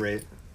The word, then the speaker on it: Ralph: Ralph. Um, is awesome. Yeah. He's great. Rafe. Wait, no, it's it's, it's Ralph. – It's it's it's spelt Ralph, but he pronounces it Rafe.